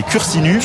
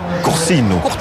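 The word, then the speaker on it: Cursinu: Si on prononce en français, c'est cursinus. – Cursinus. – Et si on prononce en corse, c'est coursino.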